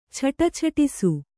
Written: ಛಟಛಟಿಸು

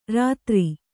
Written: ರೌದ್ರೆ

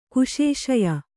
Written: ಕುಶೇಶಯ

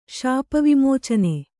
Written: ಶಾಪವಿಮೋಚನೆ

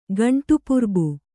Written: ಗಂಟುಪುರ್ಬು

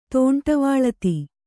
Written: ತೋಂಟವಾಳತಿ

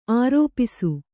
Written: ಆರೋಪಿಸು

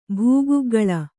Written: ಭೂಗುಗ್ಗಳ

